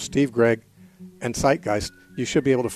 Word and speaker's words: Zeitgeist: Zeitgeist is spelled Z-E-I-T, the German word for time, and Geist, the word for spirit, G-E-I-S-T. So Z-E-I-T, G-E-I-S-T, Zeitgeist.